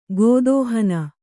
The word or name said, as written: ಗತಿಹೀನೆ